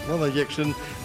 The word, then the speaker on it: Wanda Jackson: En dan heb ik het over Wanda Jackson, zeg ik het goed? Ja, Wanda Jackson.